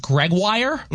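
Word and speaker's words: Gregoire: I can't spell it off the top of my head, but it's pronounced Gregwire. And I thought it was like Gregory or Gregory or something nowhere close to Gregwire.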